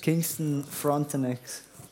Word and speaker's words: Kingston Frontenacs: Kingston Frontenacs.